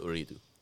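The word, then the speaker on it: Ooredoo: Like in Qatar, the telecommunications company is called Uridu, right?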